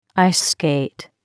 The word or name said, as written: ice skate